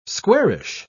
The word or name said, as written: squarish